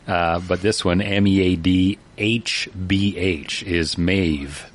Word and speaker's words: Meadhbh: Here's how her first name was spelt. Is spelt M-E-A-D-H-B-H. – H-B-H. – Yeah. – Okay. – You know how that's pronounced? – Absolutely not. – Well, it says right here, Maeve. – Maze. – Maeve. – Maeve.